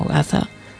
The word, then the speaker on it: Gatha: G-A-T-H-A, Gatha o Gaza, según como se pronuncie, aunque la H creo que es aspirada, y que ha subtitulado de una manera muy significativa como el primer tratado de ética de la humanidad.